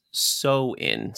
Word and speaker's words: Samhain: Known as All Hallows Eve, dates back to about 2000 years ago from ancient Celtic festivals known as Samhain, pronounced So-in.